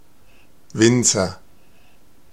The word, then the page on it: Winzer: /ˈvɪntsɐ/